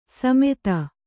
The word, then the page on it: ಸಮೆತ: kannada